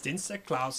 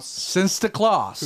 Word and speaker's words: Sinterklaas: Sinterklaas. – Sinterklaas.